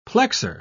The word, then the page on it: plexor: British English